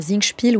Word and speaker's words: Singspiel: Un Singspiel, oui, ça se sous-entend.